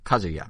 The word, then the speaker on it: Kazuya: So we had a direct where they showed – is it – how you pronounce it? Is it Kazuya or Kazuya? Kazuya, okay.